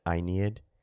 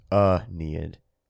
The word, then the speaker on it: Aeneid: Most people are confused when I pronounce it Aeneid. They're like, what is that? Because everyone apparently just says Aeneid. But at the end of the day, I'm just pronouncing it like a classics major does, which is that the A-E at the beginning makes a diphthong, which makes the I sound, so it's Aeneid.